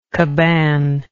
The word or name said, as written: cabane